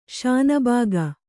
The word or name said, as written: ಶಾನಭಾಗ